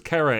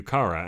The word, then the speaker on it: Kara: But after a while, you just, because it was Kara and everything else, and then the Supergirl show decided to pronounce it Kara. Do you remember we had this conversation when the show started? – I do. – On the cartoons and everything, it had always been Kara.